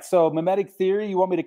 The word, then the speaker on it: mimetic: So mimetic, did I say that right? Mimetic. – You did.